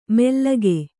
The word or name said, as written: ಮೆಲ್ಲಗೆ